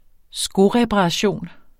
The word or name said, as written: skoreparation